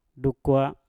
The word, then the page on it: dukwa: Mpur